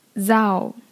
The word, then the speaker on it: thou: 那么我们看这个 thou 这个词呢其实读作 thou。